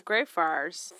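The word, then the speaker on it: Greyfriars: Gray Friars. – Yes. I think it's just Gray Friars. Friars. – Friars? – Yeah, like Friar.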